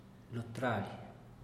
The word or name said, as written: lo traly